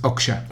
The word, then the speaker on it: Okja: No vi Okja. No, es Okja. Okja. – Oja. No, es Oja. Rara, mira, yo la vi. ¿Vos la viste, Jota? – No, al final no. – Y la pronuncian raro en la película. – A mí un amigo conocedor de la cultura coreana me insiste en que es Okja.